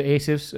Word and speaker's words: Assef: They're the Assefs, Jose and Jeremy.